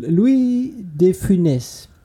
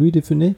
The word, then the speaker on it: Louis de Funès: Okay, jetzt dein letzter fünfter Mensch der fünfte Mensch ist, äh, und weil wir auch so schön lachen, Louis de Funès wird, wird er, glaube ich, wirklich ausgesprochen. Louis de Funès, Funès, Funès, Funès.